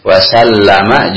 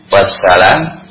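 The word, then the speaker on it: Wasallama (وسلم): Salla pakai lam itu pakai tasdid. Wasallama juga pakai tasdid. Ya. Jangan wasallam, wassalam, ya, atau wassalam. Tapi wasallama. Sallallahu alaihi wasallama.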